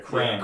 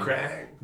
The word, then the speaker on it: Krang: Yeah, Krang. – Yeah, Krang.